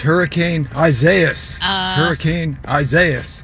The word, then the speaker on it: Isaias: So if you didn't know how to pronounce it, this was mispronounced so much in 2020 that I just wanted to let everyone know, just as it sounds out, because it's in Spanish, Isaias is going to be one that we're going to have to say a lot of this next week.